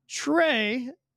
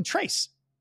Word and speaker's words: Tre: But even I know tray is spelled what? T-R-E. But how many times the mismatched pronunciation and they'll say Hyperclean Trace?